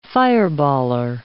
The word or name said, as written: fireballer